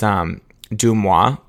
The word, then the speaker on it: DeuxMoi: It's Dumois, but it's spelled DeuxMoi.